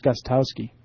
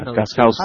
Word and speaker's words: Gostkowski: Thought it was Gostkowski. – No, it's a K. – The T is right. Silent. – The T is silent. The K is not. – Okay. Yeah, Gostkowski.